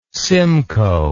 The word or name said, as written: simcoe